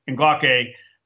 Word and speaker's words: Ngakoue: I always forget how to pronounce Yannick's name. Is it Ngakoue.